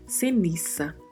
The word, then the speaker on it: ceniza: Em espanhol não temos som de Z. Então, por mais que estamos vendo aí na palavra a letra Z, vamos pronunciar com som de S. Ceniça.